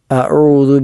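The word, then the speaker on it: അഊദു (أعوذ): ഒന്ന് അഊദു, അഊദു, അത് ശ്രദ്ധിക്കേണ്ടതായ മൂന്നക്ഷരങ്ങൾ. ഒന്നാമത്തെ അ അതെല്ലാവരും ശരിയാക്കാറുള്ളതാണ്, ആർക്കും തെറ്റില്ലാത്ത ഒരു കാര്യമാണ്, അക്ഷരമാണ്. രണ്ടാമത്തേത് ഐനാണ്, അഊ, അഊ എന്ന ഐൻ തൊണ്ടയുടെ മധ്യഭാഗത്തു നിന്നും പുറപ്പെടേണ്ട അക്ഷരമാണ്.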